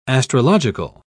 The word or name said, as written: astrological